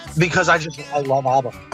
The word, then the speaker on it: ABBA: ABBA.